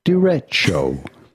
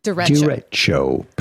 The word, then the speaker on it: derecho: How do you go about pronouncing it in English? Derecho. – Derecho. Derecho. Derecho. So this is what I'm doing. Jason Aldean's playing and I'm like, derecho. It's a Spanish word.